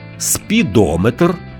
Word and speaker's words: спідометр: Якщо компонент «метр» містить назви вимірювальних приладів, то наголос там незмінно припадає на голосний звук, що передує цьому компонентові – барометр, спідометр, термометр, дозиметр.